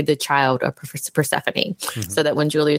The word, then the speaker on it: Persephone: Persephone or Persephone? – Persephone.